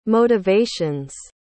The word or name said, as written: motivations